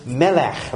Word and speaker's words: Molech: The worshippers called him Melech, which means king. But the Israelites, in pronouncing the name, put the vowels of the word meaning shame, Bosheth. So he is Molech, to say that he is not a king at all, but a shameful being.